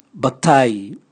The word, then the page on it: batai: Raga